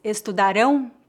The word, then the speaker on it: estudarão: No segundo exemplo, eles estudarão português, o verbo estudar está no futuro. A sílaba forte da palavra estudarão é a última sílaba, rão. Neste caso, o som do ão é bem forte. Estudarão, estudarão.